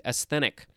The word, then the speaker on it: asthenic: I don't think it has a long E. Asthenic.